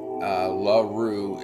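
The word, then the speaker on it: Le Roux: LaRue is spelled L-E space R-O-U-X.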